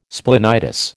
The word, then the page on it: splenitis: American English